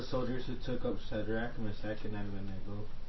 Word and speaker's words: Meshach: So that's pronounced Meshach. – That's usually the way it's pronounced.